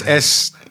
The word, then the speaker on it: SNES: SNES, it's SNES.